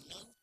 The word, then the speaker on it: know: Ok, la K aquí del verbo to know es totalmente muda. No se pronuncia. Ok, I know where he works.